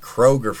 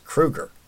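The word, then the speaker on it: Kroeger: Because for the longest time, we all were saying Chad Kroger from Nickelback. And I think it's just because we got Kroger down here stateside at the grocery stores. Let's go Krogering. No, that's pronounced Kruger. Chad Kruger. And he said nobody ever really asked me how to pronounce it.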